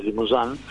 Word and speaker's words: Limousin: O sea que la raza Limousin, vamos a pronunciar como corresponde, ¿no? Porque tengo que estudiar un poquito, es una raza de origen francés, Limousin,